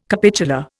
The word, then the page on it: capitular: Indian